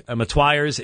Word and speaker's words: Motyer: J. Alec Matwyers, is it M-O-T-Y-E-R, that Matwyer sounds like the proper pronunciation.